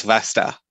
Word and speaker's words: Vasta: The rhymes with pasta, so it's Vasta.